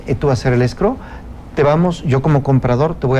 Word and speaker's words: escrow: La semana pasada traía unos clientes con una cosa que le llamaron escrow. Dices tú, ¿qué es eso? Ah, bueno, pues es una figura totalmente gringa. El escrow, para que no nos... se escribe E-S-C-R-O-W, escrow.